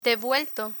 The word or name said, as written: devuelto